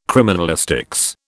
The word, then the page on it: criminalistics: British English